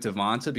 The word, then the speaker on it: Devonta: was Devonte Freeman, but we just went with Devonta because that's the way that it's spelled.